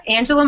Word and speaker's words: Angela: Yeah, Angela Myers. It's at Angela, A-N-G-E-L-A, Myers, M-A-I-E-R-S, all one word.